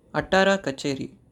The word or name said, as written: Attara Kacheri